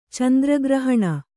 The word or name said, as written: ಚಂದ್ರಗ್ರಹಣ